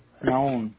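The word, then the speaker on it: Nahum: Noun.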